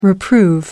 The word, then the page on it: reprove: American English